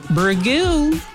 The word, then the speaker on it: Burgoo: Today's wiki word is a fun one. Burgoo. – Ooh. – Or Burgo. – Burgo. – However you pronounce it. – Burgo. – B-U-R-G-O-O is how you spell it.